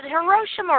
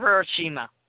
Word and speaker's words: Hiroshima: Hey, Lou, is it Hiroshima or Hiroshima? Is it Hiroshima? Hiroshima or Hiroshima? – I usually hear it pronounced as Hiroshima.